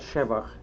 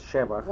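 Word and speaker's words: Szewach: My name was Shevach. – Wait, change why and to what? – That's right. The name was Shevach.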